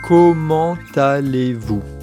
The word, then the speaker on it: Comment allez-vous: Comment allez-vous？ – Comment allez-vous？ – Comment allez-vous？ – 哇，好似咧，我哋广东话咧讲今晚打老虎咁、哦。 – 其实都有啲似嘅，不如咧，我哋再请 讲多次，正确嘅发音系 Comment allez-vous？ – 明晒，咁我哋去比利时咧，一于一齐今晚打老虎啦。